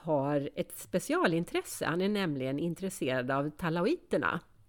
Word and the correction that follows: talajoterna: talajoterna, så uttalas det.